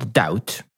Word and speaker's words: doubt: Jak na przykład doubt, mhm. – czyli wątpliwość, wątpić. No i mimo, że w pisowni jest tam b, to wymowiona znika, jest po prostu nieme.